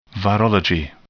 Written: virology

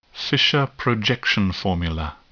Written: Fischer projection formula

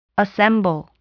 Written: assemble

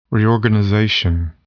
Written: reorganization

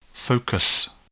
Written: focus

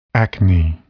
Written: acne

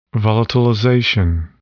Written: volatilization

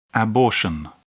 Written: abortion

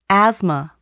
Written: asthma

